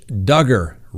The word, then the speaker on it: Duggar: Next on the docket, Josh Duggar, rhymes with hugger.